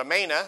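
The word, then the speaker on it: Amana: Now we call it a manna here in modern days, but a manna is a long A there.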